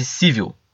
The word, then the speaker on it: Civil: Chama-se Civil, tá? A Civil, tá? De sociedade civil mesmo, com a pronúncia em inglês.